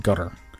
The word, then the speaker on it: Qatar: Well, in the country Qatar, this was spelled Qatar. It's actually pronounced gutter.